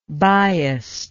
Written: biasedly

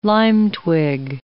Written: lime-twig

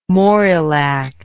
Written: morrill act

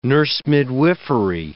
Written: nurse-midwifery

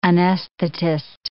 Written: anesthetist

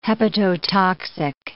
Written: hepatotoxic

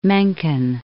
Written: Mencken

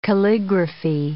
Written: calligraphy

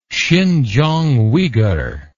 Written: sinkianguighur

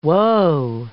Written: whoa

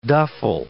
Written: duffle

